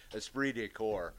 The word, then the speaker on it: esprit de corps: Y'all saying it wrong. – It's French. I could never pronounce those French words either, but I've heard it enough. It's esprit de corps.